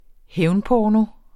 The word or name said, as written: hævnporno